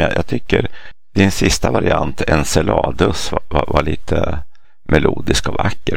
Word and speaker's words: Enceladus: På engelska så alltså säger man Enceladus med ett S-ljud. Men det grekiska ordet, när månen är namngiven efter en av giganterna i den grekiska mytologin. Och där är jag ganska säker på att ordet ska uttalas Enceladus. Med K, det skrivs med kappa.